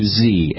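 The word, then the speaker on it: Xe: That's right, Blackwater changing its coat, changing their name to Z, and it's spelled X-E.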